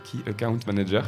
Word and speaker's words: Key Account Manager: Alors je sais pas si je le dis bien, Key Account Manager.